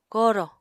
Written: korò

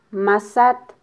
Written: masat

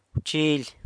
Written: wchily

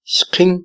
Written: xqin

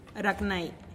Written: ragnai

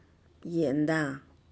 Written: yenda'a

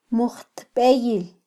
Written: mujtbeyil

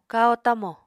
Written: kahó-tamo

